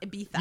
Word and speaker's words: Ibiza: I thought he was saying beaver because I didn't know that it's pronounced Ibiza.